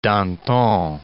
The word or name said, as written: Danton